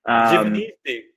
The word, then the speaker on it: Giovanisci: So my last name is pronounced, or the way I pronounce it is Giovanisci. – Giovanisci. Oh my God. – Yeah. There are other pronunciations of it. Giovanisci is, I think, the correct Italian pronunciation.